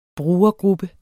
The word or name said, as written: brugergruppe